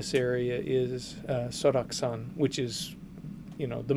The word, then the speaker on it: Seoraksan: Seoraksan. – Seoraksan.